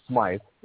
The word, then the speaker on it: Smythe: My last name's Smythe. That's the only problem you had. But it's Irish. People think it's Smith. – Irish pronounce it Smythe.